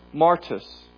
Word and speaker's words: martus: The word for witness comes from the Greek word martus, which sounds like the word martyr. Martyr is a Christian who dies because they would not waver in their faith.